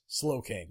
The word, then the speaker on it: Slowking: And in Crown Tundra, there'll be an item that allows your Galarian Slowpoke to evolve into a Galarian Snow King. – Nice. – Slow King. Yeah, right. I said that right.